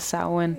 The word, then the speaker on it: Samhain: Ja, vi startede sidste år med Samhain, men det hedder det staves sådan, men det udtales Samhain.